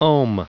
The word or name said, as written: ohm